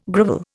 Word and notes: grovel: Indian

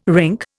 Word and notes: rink: Indian

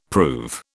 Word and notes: prove: British English

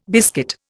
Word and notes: biscuit: Indian

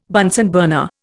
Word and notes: Bunsen burner: Indian

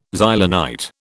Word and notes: Xylonite: British English

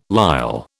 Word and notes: lisle: British English

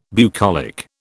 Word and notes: bucolic: British English

